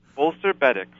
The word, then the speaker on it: Bolster Betix: Bolster Betix, B-E-T-I-X. What are Bolster Betix? – Bolster Betix.